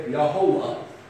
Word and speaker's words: Jehovah: J-H-V-H. But again, if you're German, how do you pronounce a J? J? No, yeah. Okay? How do you pronounce a V? with a W. So a German would say Yehovah, okay, for Jehovah.